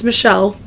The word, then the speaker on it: Mitchelle: Mitchelle. Mitchelle. It looks like Mitchell, but it's Mitchelle.